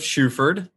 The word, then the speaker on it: Shuford: Joseph Shuford, and the last name is spelled S-H-U-F-O-R-D.